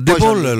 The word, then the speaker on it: De Paul: A De Paul lo prendereste per la Roma? – Di corsa.